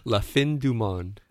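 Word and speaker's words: La Fin du Monde: Obviously, we've had the, if you've listened or you recall, longtime listeners, we did have, help me out with the pronunciation on this. Fin de Dumont. – La Fin Dumont.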